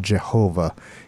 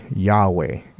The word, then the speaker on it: Yahweh: But people who are studying the original languages say it's probably not going to be pronounced Jehovah. It's probably Yahweh.